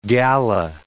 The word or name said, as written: Galla